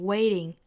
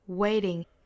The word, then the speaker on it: waiting: 后面呢 waiting, 我们说美式发成 waiting, waiting, 里面这个 t 的发音我们不这么十足的爆破啊，而是发成这个 flap t, wait, wait, waiting, waiting。后面 for 美式发法若读成 for, waiting for my favorite songs, waiting for my favorite songs.